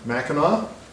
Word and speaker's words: Mackinac: So that's pronounced with a silent C at the end, Mackinac.